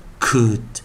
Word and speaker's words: could: 所以 could could could l 没有发音哦，像这样子的，我们来看第二个是什么？could could could 啊，第二个啊，我们来我们的 onside，我们的首音找的是什么？